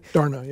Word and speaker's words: Dharna: And the plan involves something, Dharna, am I saying that correctly? – Dharna, yeah.